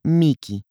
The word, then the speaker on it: mihi: En la divertida palabra tiquismiquis está el miji latino con una H, pronunciada macarrónicamente en latín escolástico como miki.